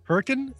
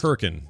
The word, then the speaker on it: Hurricane: Everyone thinks it's Hurricane Utah, but it's not. It's Hurricane Utah. – You know why everybody thinks that? – Hmm. – Because it's spelled exactly the same way as hurricane.